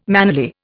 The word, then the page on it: mannerly: Indian